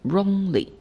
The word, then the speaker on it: wrongly: 对，你就一个音加一个音，哎，这 w 在这里不发音，所以 wrongly。 Okay, now say it again.